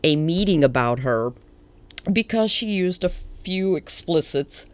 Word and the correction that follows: expletives: How do you say that? – expletives.